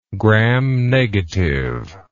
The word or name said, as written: gram negative